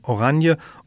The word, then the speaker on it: Oranje: Oranien spricht sich im Niederländischen Oranje und auf Französisch Orange.